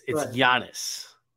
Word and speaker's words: Giannis: By the way, Pete, it's Giannis. – Whatever, shut up. – Giannis, bro. With what? Like, pretend it's a Y.